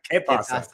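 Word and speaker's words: qué pasa: The key pasa. Is that it? Key pasa. – Que pasa.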